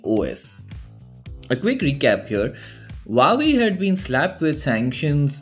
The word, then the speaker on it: Huawei: So Chinese giant Huawei, yes, that's how it's pronounced. Huawei announced three new tablets and a smartwatch this past week.